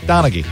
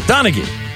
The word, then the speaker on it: Donaghy: Did I pronounce his name right? – Yeah, Donaghy. – Donaghy.